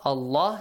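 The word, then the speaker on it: الله: It's pronounced heavily if there is a fatha or a dhamma sound right before. إِنَّ has a fatha, Allah, so there is a fatha before Allah, pronounce it.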